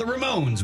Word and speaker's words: Ramone: And if we get to it, if we have time, the Ramones with I Don't Want to Go Down in the Basement. – Yeah, I like that. Ramone. – I love it. – Rhymes with Simone.